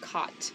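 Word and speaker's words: qat: And then another one close to amphetamine, cot, it's Q-A-T pronounced cot.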